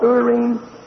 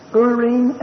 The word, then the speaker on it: Urim: As a picture, they were to be under subjection to these two mysterious stones that God put inside, called Urim and Thummim. Those are the Hebrew pronunciations. Some folk pronounce them with English pronunciations of Urim and Thummim, but actually they're Hebrew words, Urim and Thummim.